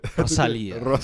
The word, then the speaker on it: Rosalía: Rosalía. – Wow. Oh. Say that again. – Should I do it one more time? – Yeah, one more time. – Rosalía.